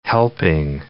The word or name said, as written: helping